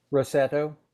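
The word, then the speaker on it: Roseto: So you talked a little bit about, is it pronounced the Rosetto effect? – Rosetto.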